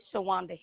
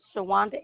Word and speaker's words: Shawanda: Okay, Shawanda Henderson. – Can you spell that for me? – Shawanda, S-H-A-W-A-N-D-A. Henderson, H-E-N-D-E-R-S-O-N.